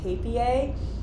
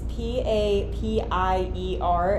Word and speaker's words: Papier: And then another favorite of mine right now is my daily planner and my travel journal, which are both from Papier. I think that's how you pronounce it. It's P A P I E R, and I believe it's a German word.